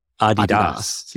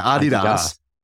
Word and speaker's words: Adidas: I believe it's pronounced Adidas. – Yeah, Adidas.